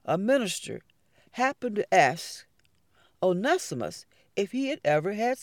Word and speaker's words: Onesimus: Now Cotton Mather gave this enslaved person the name Onesimus. And Onesimus is spelled O-N-E-S-I-M-U-S.